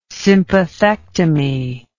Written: sympathectomy

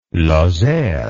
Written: Lozère

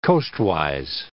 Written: coastwise

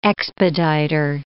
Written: expediter

